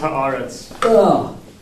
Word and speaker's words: Haaretz: It's pronounced Haaretz.